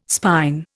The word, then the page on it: spine: Indian